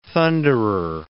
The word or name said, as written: thunderer